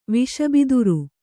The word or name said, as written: ವಿಷಬಿದಿರು